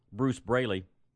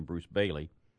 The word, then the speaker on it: Bruce Braley: This is the one that we talked about yesterday, Bruce Braley. That's the one who Michelle came on Friday and mispronounced his name seven times in the speech, called him Bruce Bailey.